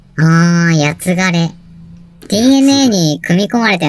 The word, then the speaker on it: 僕: 平安時代には、僕と書いて、やつがれと読みました。